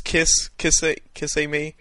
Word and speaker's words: Kissimmee: Kissimmee? Is that how you pronounce it? – Laura sent me the pronunciation earlier this week, but now I forget it because she knew we would butcher it. It's Kissimmee.